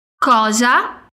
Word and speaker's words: cosa: Cosa mais uma vez, atenção à pronúncia. O O é bem aberto e o S tem som de Z.